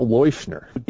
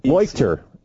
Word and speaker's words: Leuchter: It's not Leuschner. – Leuchter or Leuchter. I can't remember how he pronounces it. There's a movie about it, Dr. Death, right? I think he pronounced it Leuchter or something.